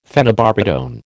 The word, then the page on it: phenobarbitone: American English